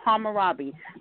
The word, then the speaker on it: Hammurabi: There was something called the Code of how can I pronounce it? – Hammurabi. – Thank you. So Hammurabi.